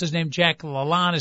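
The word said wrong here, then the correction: Lalanne: Is that Lalane or Lalonde, the great I always said it was Lalane. – Lalane. – Lalane, yeah.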